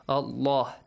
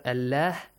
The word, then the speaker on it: Allah: يَضُرَّ اللَّهَ, Allah is pronounced heavily. Allah, this way, not Allah.